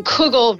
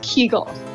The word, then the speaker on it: kugel: You do see that, though, in kugel versus kegel and punim versus punim. In America, kugel and punim became the norms, whereas in Yiddish, they are pronounced in different ways depending on what region of Eastern Europe people are from, the Litvaks versus the Galicianers.